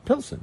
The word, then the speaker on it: Pilsen: Pilsen, right? Okay. Did I not say it right? – You threw a little Z in there, like Pilsner.